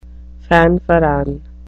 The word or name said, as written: fanfaron